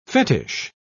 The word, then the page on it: fetich: British English